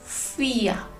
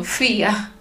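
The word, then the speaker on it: vier: Número 4, FIA. Y se escribe con FAU. ¿Recuerda FAU? V. Y recuerda que la mayoría de las veces la V en alemán se traduce como F. Así que es V.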